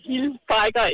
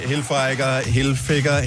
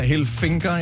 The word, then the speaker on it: Hilfiger: Kært barn hørt mange navne med hensyn til Tommy Hilfiger, Hilfinger. Jeg har hørt mange ting. Som en reklamespeaker blev engang pålagt at udtale Hilfinger, til trods for, at det ikke er rigtigt.